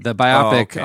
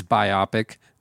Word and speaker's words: biopic: And by the way, Jesse was taught by me this week that the correct pronunciation of the word is biopic, not biopic.